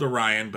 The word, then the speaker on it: Orion: it's spelled Orion, but it's pronounced Orion in the show, I believe was the second season premiere of the animated show.